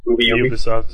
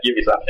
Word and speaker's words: Ubisoft: Well, I mean, like right now, Ubisoft. How do you say that? – Ubisoft is how I always say it, but yeah, Ubisoft. Anyways, Ubisoft does Rayman Legends, and you know, Rayman Legends are all the Rayman games, basically.